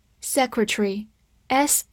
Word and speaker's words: secretary: Secretary，s e c r e t a r y，secretary，s e s e c r e c r c T A R Y，注意 A 在这里不发音，所以相当于看成 T R 发 tr，字母 Y 发 a，tree，secretary，secretary，它是一个名词，表示秘书、书记、部长、大臣。